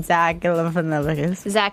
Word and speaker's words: Galifianakis: Zach Galifianakis.